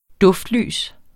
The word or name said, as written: duftlys